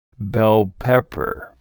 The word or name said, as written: bell pepper